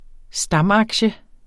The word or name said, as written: stamaktie